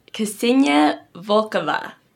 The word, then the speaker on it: Ksenia Volkova: And today I have my guest, Ksenia Volkova.